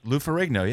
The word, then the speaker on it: Lou Ferrigno: Lou Ferrigno, yeah.